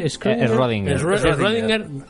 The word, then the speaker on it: Schrödinger: Schrödinger.